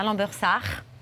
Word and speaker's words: Lambersart: Chef de la laiterie, à Lambersart.